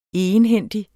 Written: egenhændig

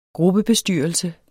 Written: gruppebestyrelse

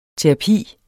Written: TERAPIENS

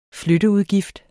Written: flytteudgift

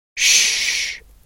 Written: ssh

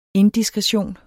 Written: indiskretion

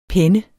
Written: penne

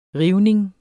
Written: rivning

